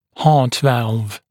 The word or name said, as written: heart valve